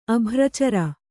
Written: ಅಭ್ರಚರ

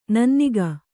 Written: ನನ್ನಿಗ

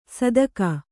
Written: ಸದಕ

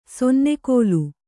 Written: ಸನ್ನೆಗೋಲು

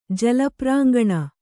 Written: ಜಲಪ್ರಾಂಗಣ

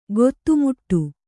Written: ಖಾತೆಮಾಡು

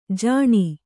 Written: ಜಾನು

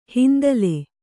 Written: ಹಿಂತಲೆ

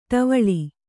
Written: ಠವಳ

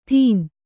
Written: ದಿನ

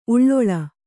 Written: ಉಳ್ಳೊಳ

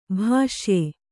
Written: ಭೂಷ್ಯ

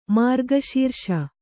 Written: ಮೃಗಶೀರ್ಷ

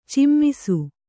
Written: ಚುಮ್ಮಿಸು

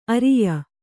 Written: ಆರಯ್ಯು

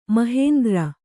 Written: ಮಾಹೇಂದ್ರ

